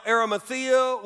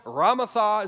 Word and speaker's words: Arimathea: Now, Arimathea was a surrounding town. Ramatha is another way that it's pronounced in the scriptures.